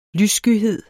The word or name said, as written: lysskyhed